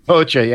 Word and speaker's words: Hoxha: Well, given it's the political DNA coming out of the paranoia of the Cold War era of this weird Enver Hoxha, is that how you pronounce it? – Hoxha, yeah. – Enver Hoxha.